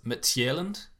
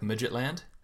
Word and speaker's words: Midtjylland: I noticed that they have renewed Aalborg from the Danish League, and they've picked up, I'm pretty sure it's pronounced Midtjylland, but I always just think it was like Midgetland. You spot the J around with the T, then it kind of reads like Midgetland.